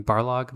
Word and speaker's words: Barlog: It was a passion project for Corey Balrog, however you say his name, Barlog. – The opposite of the giant creature in the... – Yeah, I said Balrog, which is the Lord of the Rings.